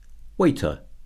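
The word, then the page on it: waiter: noun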